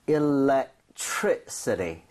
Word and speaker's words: electricity: electricity. So 이 정도의 choice. – 강세를 주고 발음을 하셔야 됩니다.